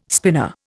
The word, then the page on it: spinner: Indian